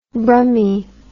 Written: brummie

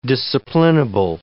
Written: disciplinable

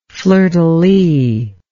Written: fleur de lis

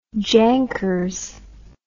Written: jankers

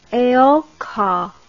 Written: eoka

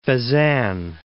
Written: Fezzan

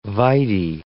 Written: vide

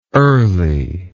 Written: earliness

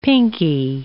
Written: pinkie

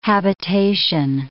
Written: habitation

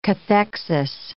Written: cathexis

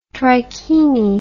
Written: trikini

